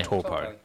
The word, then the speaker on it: Taupo: Anyway, so Brody's back, and they're racing at Taupo. Is that how you say it? – Yeah. – Taupo.